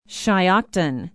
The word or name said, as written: shiocton